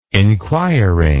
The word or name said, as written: inquiring